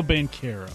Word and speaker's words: Banchero: Paolo Banchero.